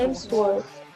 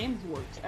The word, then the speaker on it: Hemsworth: É, Hemsworth. É Hemsworth.